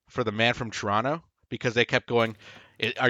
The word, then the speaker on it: Toronto: Yeah, it's pronounced Toronto. No T. No second T. – Yeah. Silent. – Silent T.